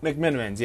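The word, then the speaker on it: McMenamins: Okay, so I'm choosing McMenamin's Edgefield Hotel. Am I pronouncing that right? – Yep, McMenamin's, yes.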